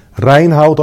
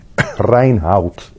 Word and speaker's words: Reinhoudt: Reinhout, I will spell. That is R-E-I-N-H-O-U-D-T. Reinhout.